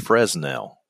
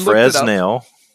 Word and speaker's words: Fresnel: So I just looked this up because I've always called it Fresnel lens. – That's because you're from Kentucky. – And as I look it up, you can pronounce it either way and it's appropriate.